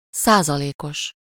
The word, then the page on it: százalékos: /ˈsaːzɒleːkoʃ/